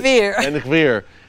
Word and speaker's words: Ben Gvir: Ben Gvir.